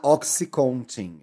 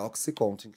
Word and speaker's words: OxyContin: E o eu medicamento chama Oxycontin. Eu falei errado, é Oxycontin que chama o medicamento.